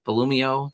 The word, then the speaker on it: Volumio: Volumio. That sounds good. – Volumio. – Volumio. – it's like volume, but you take the E off the end of it and add IO at the end.